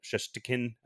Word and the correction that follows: Shesterkin: I don't know how to say his name. – Uh, Shesterkin.